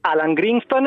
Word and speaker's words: Alan Greenspan: Alan Greenspan e Ben Bernanke.